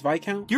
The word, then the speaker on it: Viscount: The Viscount? Is that how it was supposed to be pronounced? – Oh my god, you got that word right! – He actually got it right, yeah. – It's Viscount? – You're fucking with us!